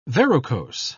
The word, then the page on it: verrucose: British English